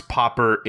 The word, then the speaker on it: pauper: And I guess I can read our last question from at Corito Prime, who asks, how do you pronounce popper in English? – Paw as in a cat's paw and purr as in a cat's purr.